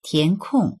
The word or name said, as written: tian2kong4